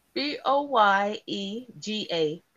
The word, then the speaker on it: Boyega: More movie actor John Boyer. Am I saying that right? Boyer. Wait, B O Y E B O Y E D what? – B O Y E G A Boyega. Boyega.